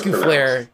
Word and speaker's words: Lescouflair: It's Lescouflair. It's Lescouflair. The S Lescouflair. Yeah.